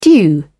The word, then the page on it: due: British English